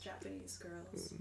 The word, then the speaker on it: JPNSGRLS: It's also spelled J-P-N-S-G-R-L-S. – Okay. But interesting. – It's pronounced Japanese Girls.